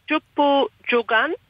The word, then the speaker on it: Jumbu Jungan: Jumbu Jungan, hold of the message stick. Right pronunciation there, sister? – Yeah. Jumbu Jungan.